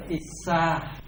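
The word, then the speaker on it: issā: Sân phần tâm sở có 4 tâm sở, đó là đô xả là sân, issa, cái này đọc là issa, issa là ganh tỳ,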